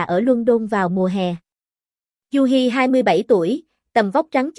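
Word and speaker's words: Yudhi: Tên anh là Yuhi, phát âm là Yuhi.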